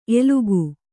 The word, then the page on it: ಎಲಗೇ: Kannada